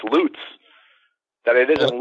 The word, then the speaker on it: Lutz: She said, and I love this quote, she said, tell Barry that the name of the town, Lutz, and she puts rhymes with Apollonia's butt, not her glutes.